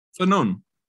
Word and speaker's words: Fanon: I quoted Francis Fanon, who I'm convinced I'm saying the last name wrong, but you know, so, what? – Fanon.